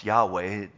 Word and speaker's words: Yahweh: And so the best of their biblical scholars say it's, we think it's provided, pronounced Yahweh.